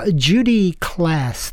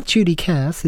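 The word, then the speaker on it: Judy Klass: Okay, you're listening to Mostly Folk, and on we go with a song from Judy Class. That's spelled with a K. It's not Judy Cass, it's Judy Class.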